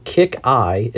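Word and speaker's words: KiCk i: So Kick Eye is the name of the album, I believe. That's how you pronounce it.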